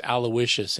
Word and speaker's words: Aloysius: In fact, I didn't even know how to say his first name. It's Aloysius, and I had to find out.